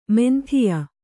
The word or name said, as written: ಮಾಂದ್ಯ